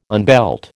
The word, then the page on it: unbelt: American English